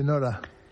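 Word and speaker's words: Norah: ¿No cambia la pronunciación o qué? – No cambia. – Nora, ¿no? – Nora. La de Ibsen suena igual, ¿no? – Como la madre de Borges, ¿no?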